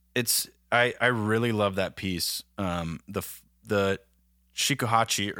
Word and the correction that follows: shakuhachi: or did I say it right? Shakuhachi, excuse me.